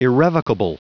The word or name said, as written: irrevocable